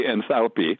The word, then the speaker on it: enthalpy: I pronounce it, by the way, enthalpy. That's what my teacher used when I took thermodynamics courses years ago.